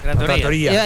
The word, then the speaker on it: trattoria: Trattoria. Ahí sí, con el acento en la I. – En los lugares donde se comen pastas. – Sin tilde, pero con acento.